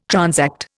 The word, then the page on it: transact: Indian